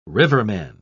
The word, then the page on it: riverman: British English